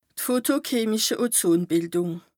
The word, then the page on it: fotochemische Ozonbìldùng: Bas Rhin